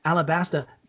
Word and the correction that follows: Ashtabula: County, I'm sorry, I mispronounced that. It's Ashtabula County, Ohio, has been slashed from 112 to 49 deputies.